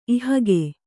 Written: ಇಹಗೆ

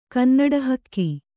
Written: ಕನ್ನಡಹಕ್ಕಿ

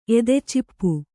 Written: ಎದೆಚಿಪ್ಪು

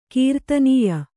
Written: ಕೀರ್ತನೀಯ

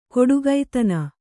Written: ಕೊಡುಗೈತನ

